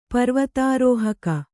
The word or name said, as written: ಪರ್ವತಾರೋಹಕಿ